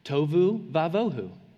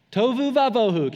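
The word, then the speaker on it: tohu vavohu: Um, and so it's, it, this is actually a really neat phrase in Hebrew, um, and it's, it rhymes. It's, it's the phrase for uh, formless and empty, tovu vavohu. Tovu vavohu. Can you say it with me now? Tovu vavohu.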